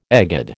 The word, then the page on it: egad: American English